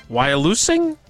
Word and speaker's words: Wyalusing: Is it Wyalusing State Park? Wyalusing? – That's how I've heard it. – Interstate State Park. – That's how I've heard that one. – Devil's Lake, not an option. Wyalusing State Park, Interstate State Park, or High Cliff State Park.